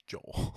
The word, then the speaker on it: Joel: Helena Hutchins, 42, and injured Joel Souza, 48, in late October. – Why'd you say Joel? – It's Joel. – Oh, sorry. Well, I read his last name, Souza. Joel.